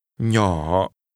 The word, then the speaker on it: Nhỏ: Nhỏ. Little is pronounced with a falling, rising tone.